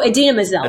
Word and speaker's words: Idina Menzel: Like I was thinking in Frozen of like, I can't pronounce her name. Ina Menzel. – Oh, Idina Menzel.